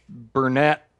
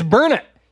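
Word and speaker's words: Burnet: And I said, yeah, anyway, we're hunting near Burnett, Texas. And he said, it's Burnett. You can't even say that right.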